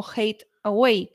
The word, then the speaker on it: Hathaway: Y la mujer de Shakespeare se apellidaba Hathaway, pero se pronunciaría en la época algo así como hate away, que es el odio lejos o fuera de aquí.